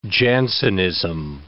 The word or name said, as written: Jansenism